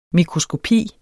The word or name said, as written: mikroskopi